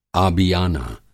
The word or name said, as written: आबियाना